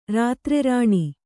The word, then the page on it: ರಾತ್ರಿರಾಣಿ: rātri rāṇi